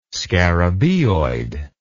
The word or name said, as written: scarabaeoid